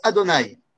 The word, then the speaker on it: Adonai: Vous savez, dans ce monde, Akadosh Baruch Hu, Yudke Vavke, se prononce Adonai.